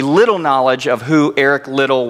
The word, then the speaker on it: Liddell: To help you remember how to say his family name, just remember that Liddell rhymes with fiddle. Repeat after me. Liddell. Come on, guys. Liddell.